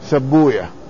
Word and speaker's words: سيبويه: ما مر بنا هذا، وأنهم يقولون في إسحاق بن راهوية ولا يستخدمون ويه، الأسماء التي تنتهي بويه لا ينطقها طلاب الحديث، فهم يقولون في سيبويه سبوية.